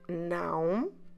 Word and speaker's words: não: And that little wave called til always indicates nasal sound. And then followed by the letter O. So you have a very nasal sound, a very nasal syllable. Não. In order to pronounce it correctly, you need to close your lips in the end.